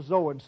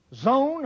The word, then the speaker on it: Zoan: Now, let me tell you about the field of Zoan. Many of you you'll worry and wonder about that one. Zoan or Zoan, depending on where you went to school, on the pronunciation, is a city in the northeast corner of Egypt in the delta.